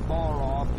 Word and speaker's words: bara: And the word is, in English, is B-A-R-A. And we would pronounce it Barah, B-A-W-R-A-W.